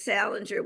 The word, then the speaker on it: Salenger: And my name is Salenger, which is Salenger. So it's spelled a little differently.